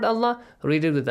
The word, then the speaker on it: Allah: Allah is pronounced heavily. Allah. This way, not Allah.